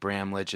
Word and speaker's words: Bramlage: I think Bramlage is right. Is that right? I think that's correct.